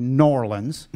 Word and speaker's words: New Orleans: And you were down in, now let's pronounce it properly, New Orleans.